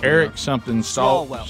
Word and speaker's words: Swalwell: You know, Adam Schiff, is it Stalwell? How you say his name? – Eric Swalwell.